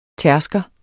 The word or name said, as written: tærsker